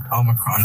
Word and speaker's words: Omicron: Omicron, that's how you say it.